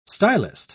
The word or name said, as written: stylist